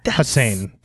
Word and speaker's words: Hossein: It's spelled H-O-S-S-E-I-N. Hussein.